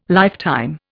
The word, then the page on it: lifetime: Indian